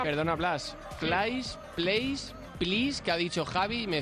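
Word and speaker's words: Pleiß: Yo diría que es Tibor Plais. – Vale, Plais.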